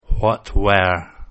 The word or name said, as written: what were